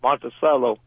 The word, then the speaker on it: Monticello: Do you pronounce it Monticello or Monticello? – Monticello. – Monticello, Georgia, then. Monticello, right.